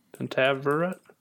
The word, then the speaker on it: Pentaverate: P-E-N-T-A-V-E-R-A-T-E. Pentaveret.